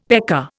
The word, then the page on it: pecker: Indian